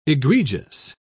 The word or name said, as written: egregious